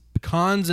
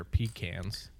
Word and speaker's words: pecan: This is Pecans in Paradise, which I've been told I'm mispronouncing, and they're pecans. – No, definitely go with pecan.